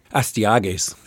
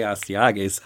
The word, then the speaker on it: Astyages: Astyages. – Yes, the son of Cyaxares. – He's the son of Cyaxares, yeah. I could say Astyages.